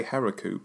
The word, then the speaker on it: Heroku: I would say Heroku, but now you're making me worry that I've got the emphasis wrong.